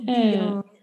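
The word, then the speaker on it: DeJong: Jill Dion. So that's the last name is spelled D-E and then J-O-N-G.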